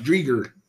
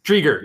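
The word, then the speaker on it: Driedger: They took three other goalies, one of which is going to be their starter from Florida, Dreider. Okay. I don't know how exactly pronouncing it, but Drieger. – Drieger, yes.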